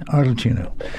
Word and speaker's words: Arlecchino: Did I say that right? Arlecchino. And A Jukebox for the Algonquin. – That's right.